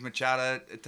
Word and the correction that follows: machaca: what? How did I say it fucked up? – Machaca. – You said machada.